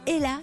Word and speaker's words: Ehla: Elle s'appelle Ella, ça s'écrit E-H-L-A.